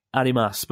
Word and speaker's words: arimasu: the key to remembering arimasu is imagine Muhammad Ali with a face mask and you're telling him to put his mask on.